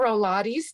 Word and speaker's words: Rolates: Info and Rolates, R O L A T E S dot com. Rolates to rhyme with Pilates.